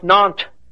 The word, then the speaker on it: Nantes: And the Edict of Nantes, as I said, in 15, pronounced Nantes, by the way, by the French, I'm pronouncing it as an American would see it, N-A-N-T-E-S, of 1598, allowed the Huguenot Church to operate.